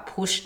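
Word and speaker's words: Putsch: push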